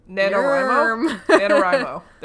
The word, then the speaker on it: NaNoWriMo: It's almost Nanairimo as well. – National Novel Writing Month. I don't know how to pronounce the condensed version. – Nerm? – NaNoWriMo. There we go.